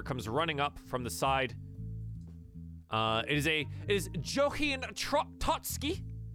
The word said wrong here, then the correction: Johanne Totsky: I'm going to go ahead and post that because I mispronounced the hell out of it. Johanne Totsky. There we go.